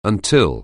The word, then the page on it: until: anglais